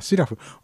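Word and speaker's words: 素面: えー、で慶次郎さんの方からいつも通りそうめんでそうめんじゃないなえっとこれ何と読むんだシラフって読むんですかのすすのすの面と書くんですけどこれちょっとググってみようえっ、とす面と読むのかあシラフとも読むんだあシラフ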